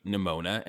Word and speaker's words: Nimona: The one that stuck out to me the most is when you fight Nimona and she goes, I always say her name and think of pneumonia and can't never not think of pneumonia.